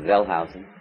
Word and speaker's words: Wellhausen: And in the 1800s especially, an approach known as Bible criticism began under the initiative of a man named Wellhausen, pronounced Wellhausen, Wellhausen, who